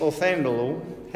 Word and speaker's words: Othniel: But the first one we have is Othniel. How do you say his name? Othniel, thank you.